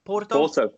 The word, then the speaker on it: Porto: No, it's Porto. – Porto, yeah. – Porto, oh, yeah. Well, yeah. There you go. – Get the pronunciation.